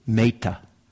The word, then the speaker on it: metta: In Pali, it's metta, Actually, the, the real, if, if you're interested, the real pronunciation is meta. Meta. But nobody in the West says meta. We all say meta. It's a gross mispronunciation, but you, sound, you just sound too silly if you say meta, so you, unless you're, so anyway.